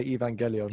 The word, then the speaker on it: Evangelion: I would pronounce that Evangelion, but I've heard people say Evangelion.